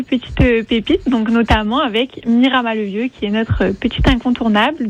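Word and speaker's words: Miramas-le-Vieux: Ah, moi, ce que j'adore, c'est que la commune, on prononce Miramas, mais pour le village perché, on dit Miramas le Vieux sans le S.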